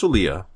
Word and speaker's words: Olija: Olia, pronounced Olia, but spelled O-L-I-J-A.